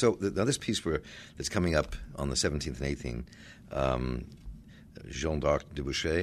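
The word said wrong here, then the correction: Jeanne d'Arc au bûcher: did I say that correctly? – Jeanne d'Arc au Boucher, yes.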